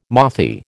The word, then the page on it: mothy: American English